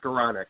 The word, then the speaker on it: Skowronek: Close. It's Skowronek.